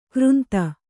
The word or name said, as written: ಕೋರಿಂದೆ